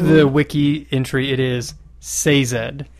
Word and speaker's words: Sazed: It's been Sazed in my head, but according to this, it's Sazed.